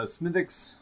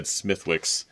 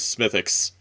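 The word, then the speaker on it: Smithwick's: I'm enjoying a Smithix Irish Red Ale.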